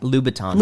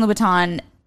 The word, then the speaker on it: Louboutin: I hope I'm saying that right. I don't know if I'm saying that right. I know how to spell it. – Louboutin. – How is it? – Louboutin. – Louboutin. – Yeah.